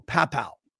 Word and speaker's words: Paopao: It's T-E-H-E-I-N-A, Tahina or Tahina. And her last name is P-A-O-P-A-O, Papow.